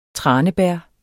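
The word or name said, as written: Tranebær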